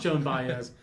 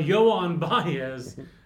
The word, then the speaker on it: Yoan Baez: I know it's not pronounced Joan Baez. But Yoan Baez, whose name is spelled Joan Baez.